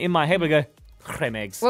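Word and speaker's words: Creme Egg: Because Cadbury caused an absolute panic yesterday when they released a statement saying that Cadbury cream eggs are actually supposed to be pronounced Cadbury creme eggs.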